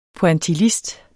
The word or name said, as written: pointillist